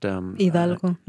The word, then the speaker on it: Hidalgo: Hidalgo.